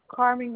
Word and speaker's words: Carmen: Carmen Gonzalez. C-A-R-M-E-N. G-O-N-Z-A-L-E-Z.